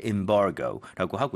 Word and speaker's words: embargo: 뭐 이런 식으로 얘기가 나오는데 여러분 아마 엠바고라고 들어보셨을 거예요. 그렇죠? 근데 실제로는 발음할 때 embargo라고 하고요.